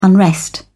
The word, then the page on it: unrest: British English